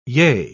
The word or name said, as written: yea